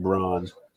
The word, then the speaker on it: Braun: Oh boy, Christian Brown, even though his last name is spelled Braun. I don't care what you say, it is Braun.